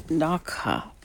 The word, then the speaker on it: Dachau: I paused it there to look up the pronunciation of this Nazi concentration camp, and I've been saying it wrong. It's Dachau. Not Dachau. Dachau. Dachau.